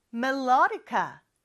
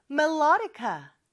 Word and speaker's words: melodica: 또 이음절의 강세가 있죠. 자, 따라해 볼까요? melodica, melodica. I play the melodica. 잘했어요.